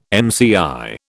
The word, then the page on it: mCi: British English